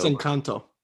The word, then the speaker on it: Encanto: They have a new movie that it looks like it's a kind of continuing down that road of Coco, but in Encanto, unless I'm saying that wrong. Enchanto. – I think it's Encanto.